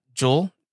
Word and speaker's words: Juul: And so the good news is that Juul, I'm pronouncing it correct.